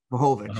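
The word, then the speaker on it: Vlahović: You talked to me, and I forgot about Vlahovic. Did I say his name right? – Vlahovic.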